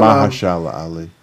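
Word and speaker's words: Mahershala: That is absolutely not how you say his first name. – Oh. Merkala. – Mahashara. – It's like a third syllable. So he was on Mahashala Ali. – Absolutely.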